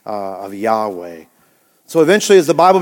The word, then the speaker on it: Yahweh: They inserted them into this tetragrammaton, and then it was rendered what we know as YAHWEH, Yahweh, Yahweh. And again, no one truly knows the exact pronunciation, but many believe that's exactly how it might have been pronounced back then, Yahweh.